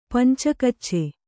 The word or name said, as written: ಪಂಚಕಚ್ಚೆ